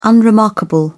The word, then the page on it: unremarkable: British English